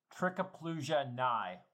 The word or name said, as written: Trichoplusia